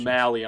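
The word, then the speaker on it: Mahle: Is it Molly? Am I saying that right? Molly. I should have really looked up these pronunciations. I'm going to go with Mally on this one.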